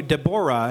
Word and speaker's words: Deborah: Let's call you Deborah. And it turns out in Hebrew, that's the more correct way to pronounce it.